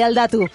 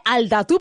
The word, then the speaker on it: Aldatu: de Aldatu. Leire, eguerdión. – Eguerdión, caixo. – Bueno, antes de nada, vuestra agencia se pronuncia así, se dice Aldatu, porque se escribe Alda con el número 2 y U.